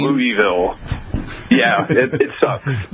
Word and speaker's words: Louisville: And it's Louisville, by the way. Louisville, Kentucky. – Louisville. As I understand, there's a bit of an argument as to pronunciation, even right in the midst of Louisville. – Yeah, if you're born from here, it's Louisville.